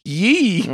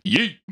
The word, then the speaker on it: Ye: Well, I still don't think I'm saying it right. I think it's Ye. – Yee. – Yeah, because both capitalized, both letters. Oh. Y and E. But it's one E, so I don't know how long I'm supposed to hold it. – What the fuck? – It's not yee. – No, it's just yee.